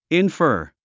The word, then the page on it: infer: infə́ː r; インファー